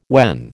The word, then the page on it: when: American English